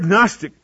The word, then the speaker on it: Gnostic: The word Gnostic is G-N-O-S-T-I-C. You say, well, what are you doing with the G? You don't pronounce it. You just say Gnostic. It's silent as far as the pronunciation of the word, but it starts with a G, and it's a very old heresy that was in the church.